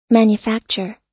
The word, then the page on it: manufacture: American English